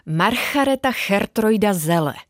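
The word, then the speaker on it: Margaretha Geertruida Zelle: Margareta Gertruida Zele.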